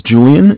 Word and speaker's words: Julian: All right, my name is Julian. It's J-U-L-I-A-N.